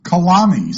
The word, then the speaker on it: calami: Kashaw, C-A-S, C-A-S-H-A-W, an alternative spelling is C-U-S-H-A-W, is a squash. A kashaw or a kushaw. Kalami, C-A-L-A-M-I, rhymes with salami, is a marsh plant and has the anagram kamail, C-A-M-A-I-L, which is a piece of armor.